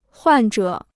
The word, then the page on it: 患者: huàn zhě